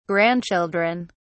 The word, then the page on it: grandchildren: British English